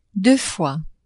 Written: deux fois

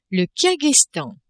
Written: Kirghizstan

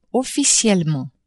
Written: officiellement

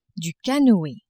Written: canoe

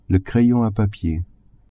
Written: crayon a papier

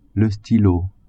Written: stylo

